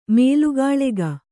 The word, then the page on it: ಮಲ್ಲಗಾಳೆಗ: mallagāḷega